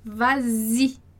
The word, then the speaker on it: vas-y: A gente tem va-i, quer dizer, vá lá. Então, para facilitar a pronúncia, a gente vai colocar o S, para conseguir fazer a liaison. Então, a gente vai dizer vas-y, tá? Porque va-i, dá um problema ali, de encontro, né, no som, de encontro de vogais, va-i.